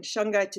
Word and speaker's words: shungite: and shungite is spelled S-H-U-N-G-I-T-E, shungite, it comes from, I think, Siberian area, somewhere in Russia.